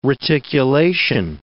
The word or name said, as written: reticulation